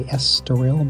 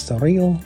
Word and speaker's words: Estoril: I would say Estoril, but the E is apparently silent, so it's Estoril.